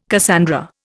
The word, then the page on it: Cassandra: Indian